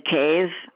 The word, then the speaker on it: Cave: Penny Cave. – Can you spell that last name? – C-A-V as in Victor, E. – So C-A-V-E? – Right. – And the first name is K? – Penny, P-E-N-N-Y.